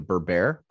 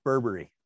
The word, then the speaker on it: Berbere: Do you, you pronounce it Berber? Berber? – Uh, Berbere. – Berbere.